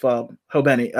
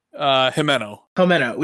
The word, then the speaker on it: Himeno: So out there, especially like it's they give you enough, because we did have the death, like the sad death with Hobeni. Did I say it right? – Himeno.